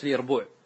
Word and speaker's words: اليربوع: ثم فاعلا مثل قاصعا، جحر من جحرة اليربوع، واليربوع بالياء وليس بالجيم كما ينطقه بعض الناس.